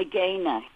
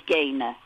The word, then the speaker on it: Gaynor: Yeah, my family call me Gaina. I know it's spelled Gaynor, but everybody says Gaina.